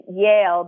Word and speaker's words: Yael: Well, I will say my middle name is Yale, but it's spelled Y-A-E-L, like Yael.